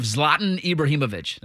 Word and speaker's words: Zlatan Ibrahimovic: Also, do you enjoy the egotistical nature of Zlatan Ibrahimovic.